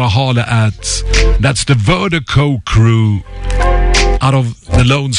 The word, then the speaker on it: Virdiko: It's the global music promotion, and Vertigo is spelled V-I-R-D-I-K-O. That's V-I-R-D-I-K-O, pronounced Vertigo.